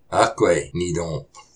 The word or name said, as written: Aquay nitôp.